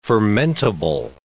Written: fermentable

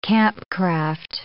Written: campcraft

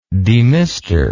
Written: demister

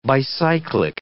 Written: bicyclic

